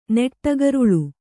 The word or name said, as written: ನೆಟ್ಟಗರುಳು